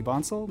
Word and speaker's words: Bonsall: We're joined today by Leigh Bonsall.